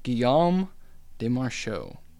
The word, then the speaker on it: Guillaume de Machaut: So, this episode we are talking all about Guillaume de Demarchaux. I say that right? Yeah. So, he is born in the 1300s, right? – He's born.